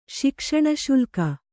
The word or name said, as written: ಶಿಕ್ಷಣಶುಲ್ಕ